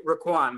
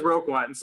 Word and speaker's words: Roquan: Yeah, and even so, it's almost like if the Bears go into the playoffs without Raquan, his mom pronounces his name Raquan, by the way. So I try to, you know. – That makes sense. Out. Thank you. – He still says Roquan, so I think it's fine to say Roquan, but, you know, I feel like his mom named him. It probably should be Raquan.